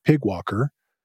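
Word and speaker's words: PyGWalker: It's spelled PYG Walker, and it's based on a project called Graphic Walker. Though, since it's PYG, they have a fun way of just pronouncing it Pig Walker.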